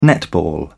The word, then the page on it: netball: British English